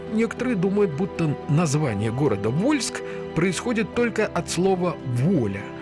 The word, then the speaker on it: Вольск: Потом произношение меняется на «Волжск», а потом уже смягчили, сами жители стали говорить «Вольск».